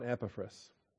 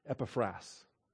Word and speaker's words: Epaphras: Let's talk about Epaphras. Epaphras, Epaphras, Epaphras. I've heard the accent on all 3 syllables. Greek puts the accent on the final alpha, so I guess we should call him Epaphras.